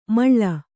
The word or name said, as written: ಮೇಳಿ